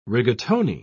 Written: rigatoni

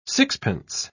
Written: sixpence

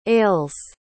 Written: ales

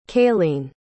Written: kayleen

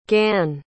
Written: gann

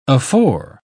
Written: afore

